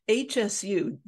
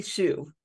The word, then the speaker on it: Hsu: One of the doctors has our technology in Redmond, California, and her name's Dr. Jenny, I can never say it right, HSU, Jenny Hsu, Dr. Jenny Hsu,